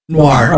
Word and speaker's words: noir: Film Noir, I think is how you say that. – Noir. – Okay, yeah. I knew I was saying the word wrong.